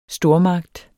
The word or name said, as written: stormagt